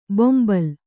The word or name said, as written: ಬೊಂಬಲ್